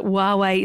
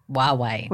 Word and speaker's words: Huawei: The best thing, Claire, about this being a podcast is that right off the bat, we can clarify how people should actually pronounce Huawei. When it's written, it's tough to unpick, but we can confirm that Huawei is the correct pronunciation. Say it after me.